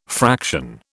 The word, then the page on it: fraction: British English